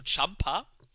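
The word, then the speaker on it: Ciampa: Und zwar Tommaso, jetzt Entschuldigung für die Aussprache falsch, denn er hat geschrieben, sein Name wird ausgesprochen wie Tampa oder Tampa, also Champa.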